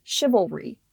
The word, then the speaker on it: chivalry: I had to go chivalry. I'm like, there's three syllables here, not two.